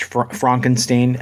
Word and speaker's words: Frankenstein: I want to watch Frankenstein, and that is the correct way to pronounce it, Paul.